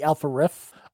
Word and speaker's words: Alpha Riff: And for Black History Month, I'm going to give the shout out to the Alpha Riff. Yes, I pronounced that correctly.